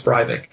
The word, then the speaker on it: Breivik: and a commenter there confirmed that that's their idea too in Norway as to how to pronounce it. So it's Breivik.